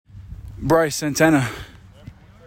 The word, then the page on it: Bryce Santana: BRICE san TAN nah